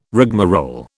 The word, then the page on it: rigmarole: British English